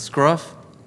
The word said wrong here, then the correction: Scrunt: Scrunt. – Scrunt. I always want to say Scruff. So Scrunt. Every time Scrunt poked me with the dagger, it actually hurts, and I just pretend it doesn't.